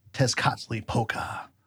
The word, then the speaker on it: Tezcatlipoca: I want to take on like the Aztec god of hell, but I'm not sure how the Nahua works there because usually the TL sound has like a sound at the end for the L. But Tezcatlipoca, Aztec god of hell.